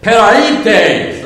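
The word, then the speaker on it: peraites (πραΰτης): Now, why do I say it like that? Because I listened on the web to make sure I was going to say it right. And when I did, the guy that said it said, peraites!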